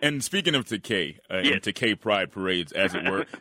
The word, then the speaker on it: Takei: And it's so silly and it's so stupid that I said, well, since my surname Takei rhymes with gay, then they can use Takei in its stead.